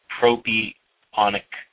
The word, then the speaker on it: propionic: propionic, I can't even pronounce it.